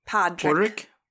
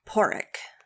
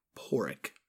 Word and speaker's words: Padraic: Yeah, it's like P-A-D-R-A-I-C. – Yeah. – Patrick. – Patrick. – Patrick. But they pronounce it in the film Porrick. – Porrick. – Porrick, yeah. So it's like P-A-W-R-R-I-C-K. Porrick.